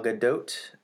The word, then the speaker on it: Gadot: I always thought it was Gadot, too. And then I looked it up, and it was like, it's pronounced with a hard T at the end.